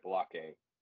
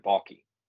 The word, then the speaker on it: Baalke: I sat there with Trent Baalke. I said that wrong. Trent Baalke.